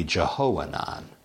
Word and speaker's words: Jehoanan: It's usually, I think, if I'm remembering this correctly, it's on the third syllable. So the third from the end. So it'd be Jehoanan married the daughter of Meshulam, son of Berechiah, right?